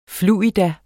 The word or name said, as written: FLUIDA